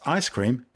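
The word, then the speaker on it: ISS-CREAM: Included in the payload for CRS-12 will be the ISS CREAM, pronounced Ice Cream, Cosmic Ray Detector.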